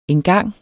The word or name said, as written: engang